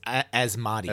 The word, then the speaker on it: Asmodee: Not Asmodee, Asmodee. Asmodee? How do you pronounce that? – I think it's the Ma. It's Asmodee. – Asmodee. Asmodee, okay.